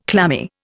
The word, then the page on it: clammy: Indian